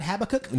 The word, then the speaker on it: Habakkuk: You're not going to hang out in Habakkuk? – No, I can't tell you that we'll be there. – Did I pronounce that right? You did all right.